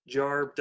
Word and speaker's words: Jorb: Uh, you know, one thing they uh, point out is that Jarb doesn't actually have any extra syllables, um, but Bad was like sarcastically saying that he should add more syllables to it, which he does on some of them. But uh, Jarb doesn't have it's still a one syllable thing.